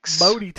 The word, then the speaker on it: Boaty: Bodhi Tanks. – Sorry, how do you spell that? – Like boat, B-O-A-T-Y, okay.